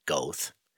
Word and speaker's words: Goethe: And then a young Johann Goethe, or as I pronounce it, Goethe, G-O-E-T-H-E, saw Faust puppet show, a little puppet show featuring Dr. Faust and the devil.